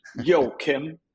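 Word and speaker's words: Joakim: Imagine a rapper greeting the North Korean dictator. Joachim. That will get you in the vicinity.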